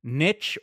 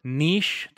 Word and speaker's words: niche: But I hope it takes off, and I hope people like it, because I think it's something that is niche or niche, just depending on how you want to pronounce it, Sindarin.